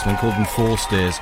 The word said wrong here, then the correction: Forestears: I think it's forest ears. Or it could be forest ears. It's like forest ears, foresters, only with an A in it. For, F-O-R-E-S-T-E-A-R-S, if you want to get on Bandcamp.